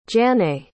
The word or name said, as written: jannae